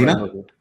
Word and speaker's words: Balina: Balina. – But how do I spell that? – B-A-L-I-N-A. – B-A-L-I-N-A.